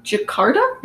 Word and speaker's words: Jakarta: In, actually, I don't know how to say it, Jakarta? – Mm-hmm.